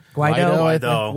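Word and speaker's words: Guaidó: Guaido.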